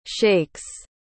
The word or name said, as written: sheiks